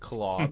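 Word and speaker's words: Klaue: But they didn't spell it like claw, did they? – But it's pronounced claw.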